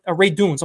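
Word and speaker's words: Radunz: Then you just leave Dylan Radins over there. Radunes, I'm sorry. People crucify me for how I pronounce his last name. Radunes.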